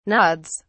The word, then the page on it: nods: British English